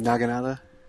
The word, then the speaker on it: Naginata: It's a Naginta, right? – You mean Naginata? – Or that too.